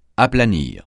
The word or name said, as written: aplanir